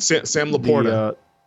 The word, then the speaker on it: LaPorta: I mean, Detroit got rid of him and replaced him with Sam LaPresta, I think. How do you say that last name? – Sam LaPorta. – LaPorta, right.